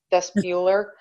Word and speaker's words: vestibular: And then one is, I'm going to probably say this wrong, vestibular. – Vestibular? – Bueller. Vestibular. Thank you.